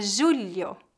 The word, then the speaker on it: julho: A palavra julho, com LH, refere-se ao sétimo mês do ano. Note que o dígrafo LH é pronunciado mais ou menos como as letras LI.